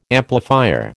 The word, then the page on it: amplifier: American English